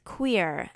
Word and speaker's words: Cuir: This Thursday, August 2nd, is Queer, and that is spelled C-U-I-R.